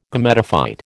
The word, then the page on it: Gametophyte: American English